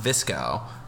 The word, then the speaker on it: VSCO: V-S-C-O, pronounced visco. – V-S-C-O? – Yeah.